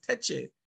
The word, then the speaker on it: Tacky: And Tacky, which is pronounced by, by um, the Akan people as Teche, Teche um, he organized it in that way where each parish would have a sort of chief.